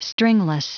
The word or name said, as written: stringless